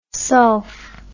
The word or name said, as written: sulf